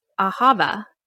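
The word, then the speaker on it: ahava (אהבה): And you said love in Hebrew is ahava. Am I pronouncing that right? – That's right.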